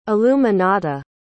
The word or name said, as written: illuminata